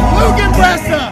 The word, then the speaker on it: Lügenpresse: Lügenpresse! You said it right.